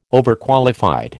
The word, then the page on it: Overqualified: American English